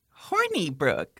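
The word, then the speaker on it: Hornibrooke: And also, it's kind of cute the way it's spelled. It's H-O-R-N-I-B-R-O-O-K-E. Horny Brook. Like, I like the I like that. Yeah, yeah, it's like horny, but it's like a little more innocent.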